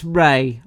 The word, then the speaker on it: Rhea: it's Rhea. I couldn't Rhea tell you why, but it is Rhea.